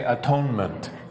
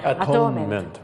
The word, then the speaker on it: atonement: Okay, at one meant. – 言ってみましょうか。 – At one meant. はい。 Actually, we say atonement. – でこれを早く言うとこういう発音になるんですね。 – Atonement, right?